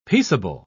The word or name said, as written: peaceable